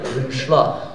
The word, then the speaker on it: unsjla: Så kan du uttale det på norsk 'unnsla', og det staves u-n-s-j-l-a. Unnsla.